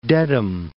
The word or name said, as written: Dedham